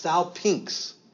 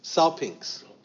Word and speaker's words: salpinx: This actually, with this G-Y at the end, this is pronounced salpinx. Salpinx. Okay, like P-I-N-X, you know, salpinx.